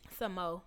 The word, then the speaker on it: SAMO: That's how you say his name, right? – Samo. It's like, I want Samo.